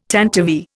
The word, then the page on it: tantivy: Indian